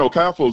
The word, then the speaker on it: Okafor: Like, the R is silent, but I'm like, I can't blame them. I gotta blame the player for it. And Okafo is not even that hard of a name.